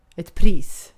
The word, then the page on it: pris: /priːs/